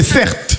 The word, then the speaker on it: Certe: Mon prénom, c'est Cert. Ça s'écrit C-E-R-T-E.